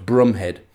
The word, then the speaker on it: Bromhead: Today, we meet his right-hand man, Lieutenant Gonville Bromhead, which is actually pronounced Brumhead.